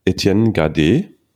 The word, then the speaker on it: Etienne Gardé: Heute mit Etienne Gade. Grüß dich. Hallo. Servus. Ich hoffe, ich habe es richtig ausgesprochen. – Ja, war komplett. Sehr, sehr ja. gut, sehr gut.